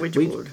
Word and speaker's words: Ouija: The other film that I checked out was I don't know exactly how this is pronounced, but is it Ouija? Like, as in Ouija board? – Ouija board. – Yeah, it's Ouija, isn't it? Yeah. Ouija. Anyway, so it's called Ouija.